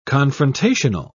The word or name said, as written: confrontational